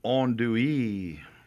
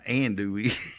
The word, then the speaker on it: andouille: David Hubble says it's andouille. Not andouille. – Right. Yeah, andouille. – Andouille. – Noted, andouille. David. Noted. – Actually, if you did it phonetically, you would take A-H-N, like on andouille. Yeah, that's French. – Yeah. – Yeah, he spelled it like O-N, on.